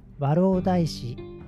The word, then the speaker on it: 円座石: それでは 1 つ目のニュースです和老大師そばに焚き火跡世界遺産熊野古道を破壊する抗議和歌山まず最初に和老大師という言葉の説明をします和老大師は漢字で円座る石と書いて和老大師と読み